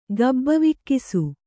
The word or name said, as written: ಗಬ್ಬವಿಕ್ಕಿಸು